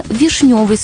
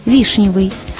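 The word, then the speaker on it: вишнёвый: Сегодня правильно «вишневый сок», «вишневое варенье», «вишневый сад», а устаревшее ударение «вишневый».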